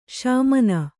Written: ಸಮಾನ

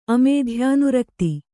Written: ಅಮೇಧ್ಯಾನುರಕ್ತಿ

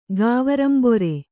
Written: ಗಾವರಂಬೊರೆ

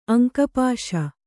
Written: ಅಂಕಪಾಶ

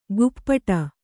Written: ಕುಪ್ಪಟ

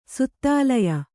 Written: ಸುತ್ತಾಲಯ

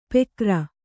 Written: ಪೆಕರ

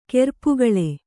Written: ಕೆರ್ಪುಗಳೆ

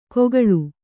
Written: ಕೊಗಳು

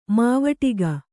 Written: ಮಾವಟಿಗ

